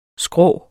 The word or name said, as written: skrå